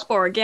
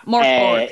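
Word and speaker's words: Mörk Borg: Morkborg, yeah. – Yeah. – Morkborg. – Yeah, Morkborg.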